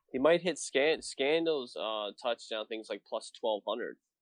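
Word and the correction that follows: Marquez Valdes-Scantling: Marquez Valdez Scantling. You were close.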